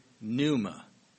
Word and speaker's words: pneuma: The spirit, that word comes from the Greek pneuma. Pneuma. I'll spell it here. P-N-E-U-M-A. Pneuma. P-N-E-U-M-A.